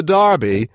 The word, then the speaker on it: Derby: For the 238th time, the Derby, pronounced in the U.K. as the Derby, will be run at Epsom Downs in Surrey, just outside London.